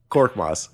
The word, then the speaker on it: Korkmaz: What's his last name? Korkmaz? Am I saying it right? – Korkmaz. Yeah, Korkmaz. You're doing it right.